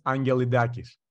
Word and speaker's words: Angelidakis: Oh, Christos, I probably should have asked you how to say your last name. – It's totally fine, Susan. It's Angelidakis.